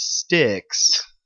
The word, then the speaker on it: Styx: Sonic has a new friend, and her name is Styx. Like the band, like the rock band Styx. – I think it's actually spelled like Styx. – It's just like Sticks and Stones.